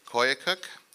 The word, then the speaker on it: Koyukuk: She was raised in rural Alaska Native villages, including Koyukuk, Kaniganek, Naknik, Nikolai, and Selowik. How'd I do in the pronunciation there? Good.